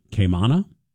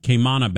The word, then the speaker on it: Kaimana: I think I'm saying that right. K-A-I-M-A-N-A. Kamana? That sounds better. Sounds more exotic. Kamana bath salts.